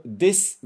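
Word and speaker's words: Des (です): A gente escreve D-E-S-U, né? Mas a gente não vai pronunciar esse U, lembra? A gente não vai falar desu, né? A gente não fala desu nem desu. Né? A gente não pronuncia o U. Então, na prática, é como está aqui do lado, a gente só pronuncia até o S.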